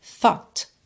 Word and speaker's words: thought: E vê a palavra escrita, T-H-O-U-G-H-T, mas é thought, thought.